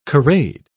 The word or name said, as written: corrade